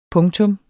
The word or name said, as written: punktum